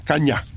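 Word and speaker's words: Canha: Pero no es Caña con ñ, es H-A, Caña. Nació en California. Sí, es de origen portugués. – ¿Cómo se escribe eso? – Caña. – Sí. – Es portugués, es Caña en portugués.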